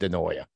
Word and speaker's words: DeNoia: Nick DeNoia is everything that Nick DeNoia, that's how it's pronounced, DeNoia.